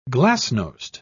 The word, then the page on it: glasnost: British English